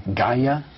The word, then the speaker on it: Gaia: Gaia? – Yes, Gaia. – How do you spell that? – I think it's G-A-I-A.